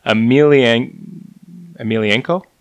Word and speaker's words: Emelianenko: I'm going to butcher this. Emelianenko. – Yeah, that's great. You got her.